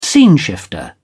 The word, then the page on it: sceneshifter: British English